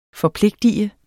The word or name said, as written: FORPLIGTEDE